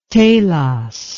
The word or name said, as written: Talos